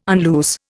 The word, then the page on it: unloose: Indian